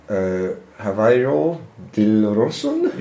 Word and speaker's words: Javier Dilrosun: Javier Dilrosun? – Yes.